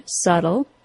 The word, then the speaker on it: subtle: Since the letter B is silent in the word subtle, the T sound now finds itself between the short U vowel sound and an L sound. This means that, in North America, that T sound becomes a quick D sound.